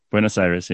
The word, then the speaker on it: Buenos Aires: If you want to go from Buenos Aires, am I saying that right? – Yeah. Buenos Aires.